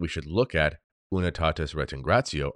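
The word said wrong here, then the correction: Redintegratio: So here is the document Unitatis Redintingratio. I was saying it wrong. It's not Retingratio, Rediting, Reditingratio.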